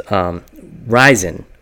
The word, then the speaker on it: Rizin: there was Ryzen, which is spelled R-I-Z-I-N.